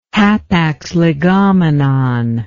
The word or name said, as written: hapax legomenon